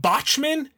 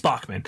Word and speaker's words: Bachman: ain't seen nothing yet which I call them Botchman, and she uh, corrected me. It's Bachman.